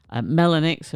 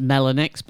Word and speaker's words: Melonyx: The last tune was by, I believe it's pronounced MELONYX, but I'm just making it up as I go along. Anyway, it's spelled melon with YX on the end.